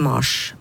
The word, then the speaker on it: Mars: Og Martius ble da ifølge Simonsen muligens uttalt med noe kortere A, i alle fall på det tidspunktet det ble lånt inn på dansk og senere på norsk. Og det her kan være forklaringen på at Mars, riktig. Navnet på måneden uttales med kortere A hos oss.